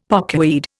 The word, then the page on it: pokeweed: Indian